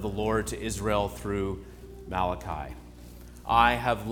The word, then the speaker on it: Malachi: In Hebrew, it's Malachi, which is my messenger. The E sound at the end of the Hebrew word is the personal possessive my.